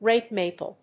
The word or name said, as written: red maple